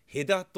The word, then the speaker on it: 戸田: で沼津港で、えー、埼玉県の戸田っていう場所がありますがあの戸田というふうに同じ字を書いて「へだ」と読む場所がある。